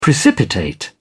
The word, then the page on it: precipitate: British English